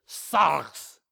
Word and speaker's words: sarx: Sarx. The R, you have to kind of roll it, pretend you're a little bit Scottish. Sarx is how you pronounce that.